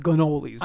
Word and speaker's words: cannoli: Because he pronounces it like a true New York Italian, because that C becomes a G. – Right. – He pretty much says like, "Oh, they made the best cannolis."